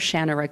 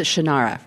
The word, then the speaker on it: Shannara: Okay, it's 76, Sword of Shannara came out, and I pronounced it the Sword of Shannara like everybody else in the whole of the United States, and that's not how it's pronounced. No, wait a minute. No, I pronounce it Shannara, right?